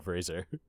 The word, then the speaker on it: Fraser: That's how his name's pronounced, man. I don't know what to tell you. I listened to episode 7 of the Scrubs podcast, and he says his name is pronounced Fraser.